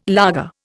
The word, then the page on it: laager: Indian